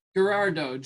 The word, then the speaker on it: Gerardot: Gerardo.